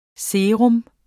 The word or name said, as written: SERAENE